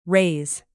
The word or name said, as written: raze